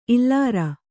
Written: ಇಲ್ಲಾರ

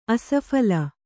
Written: ಆಸ್ಫಾಳ

